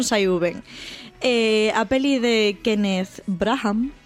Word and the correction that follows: Kenneth Branagh: non sei se estou pronunciando ben. Kenneth Branagh.